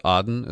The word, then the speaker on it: Aden: This all aimed at the southern port city of Aden. I've heard it pronounced Aden as well.